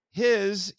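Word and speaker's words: hi (火): But for Jaden, his you pronounce either hi or ka, and it means fire.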